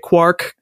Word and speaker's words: quark: And so that was his justification for pronouncing it quark, because it's like quart.